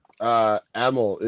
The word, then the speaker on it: Emil: EMIL. It's like Emily without the Y.